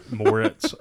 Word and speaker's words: Moritz: And Chloe Grace Mortis, Moritz, as Patricia.